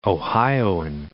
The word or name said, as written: Ohioan